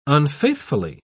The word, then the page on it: unfaithfully: British English